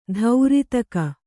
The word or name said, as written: ದಾರಿದೆಗೆ